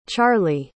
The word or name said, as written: charliee